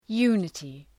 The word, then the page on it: unity: ‘ju:nətı